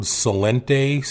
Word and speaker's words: Celente: The other guy I really like listening to is Gerald Celente, C-E-L-E-N-T-E.